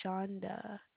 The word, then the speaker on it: Shonda: My name is Shonda. – Okay. Could you spell that for me, young lady? – S-H-O-N-D-A.